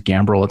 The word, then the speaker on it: Gambrill: My last name is Gambrill; It's spelled G A M B R I L L.